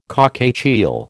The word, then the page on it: cockatiel: American English